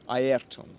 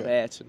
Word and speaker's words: Ayrton: Yes, Ayrton, because that's how you should pronounce it, not Ayrton.